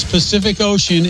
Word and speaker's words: Pacific Ocean: Every letter C in the words Pacific Ocean is pronounced differently. First one is like an S. Second one like a K. And the last one like an SH.